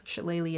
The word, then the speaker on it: shillelagh: None of Irish is spelled like it sounds. Shillelagh is like shillelagh.